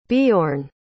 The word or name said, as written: Beorn